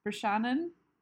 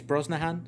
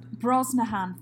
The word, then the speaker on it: Brosnahan: and I am thinking of Rachel Brosnahan. Said her name wrong? – I think it's Brosnahan. – Brosnahan, thank you. That's me squishing it together and not quite reading it properly.